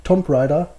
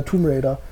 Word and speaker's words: Tomb Raider: Ich habe damals, kennt ihr auch denn das Phänomen, wenn ihr damals ein Wort falsch ausgesprochen habt, ich habe damals wirklich immer gesagt, Tomb Raider. – Das sage ich immer noch. – Ja, aber mich heißt es ja Tomb Raider.